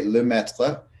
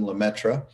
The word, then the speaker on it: Lemaître: Lemaître was born, and I'm using the American pronunciation of what a Frenchman would say, Lemaître, and I'm not going to do that for the whole evening to spare your ears. We'll use the Americanism, Lemaître.